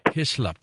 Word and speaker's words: Hyslop: James, was it Hyslop? Is that how you pronounce his last name? – Hyslop.